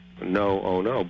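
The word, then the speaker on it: NO-ONOO: ONOO-, but pronounced no-ono because that's the way the people who suffer feel.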